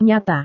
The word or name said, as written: nyata